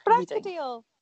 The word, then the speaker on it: Bracadale: In 2012, Fraser went on trial again in the High Court in Edinburgh before Judge Lord Bracadale, with Advocate Deputy Alex Prentice. – It's Bracadale.